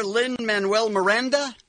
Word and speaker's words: Lin-Manuel Miranda: Hamilton star Lin-Manuel Miranda. – Mm-hmm. – Am I saying that right? – Yep. – Yep.